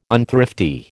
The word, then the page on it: unthrifty: American English